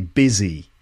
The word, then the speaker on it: busy: Most people remember that business is pronounced like that, business, but a lot of people forget that busy is also pronounced with a biz at the end. So that's hopefully a mnemonic that can help you remember that business and busy both have the same sound at the beginning. Okay, busy business. So just sort of that might help you remember that it's busy. So you could repeat that after me, busy. Yeah, not buzzy or buzzy, but busy.